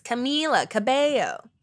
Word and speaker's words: Camila Cabello: Because she used to have that as her like Twitter display name was like how to pronounce her name. She was like, it's Camila Cabello.